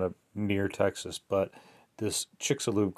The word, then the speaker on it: Chicxulub: So um, upon lo- looking it up, we find that supp- according to scientists, supposedly this Chicxulub, it's C H I C X U L U B Chicxulub crater. Uh, that's how they want you to spe- uh, pronounce it anyway.